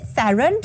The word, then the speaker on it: Theron: Am I saying it right? Is it Theron? – Yes.